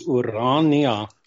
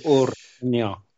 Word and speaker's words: Urania: In Afrikaans, the pronunciation is Urania. – Okay. – With the accent on the first A, Urania.